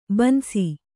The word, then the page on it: ಬನಸಿ: banasi